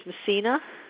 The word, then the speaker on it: Messina: Okay, last name is Messina. – Could you spell that for me? – M as in Mary, E-S-S-I-N-A. – I-N-A.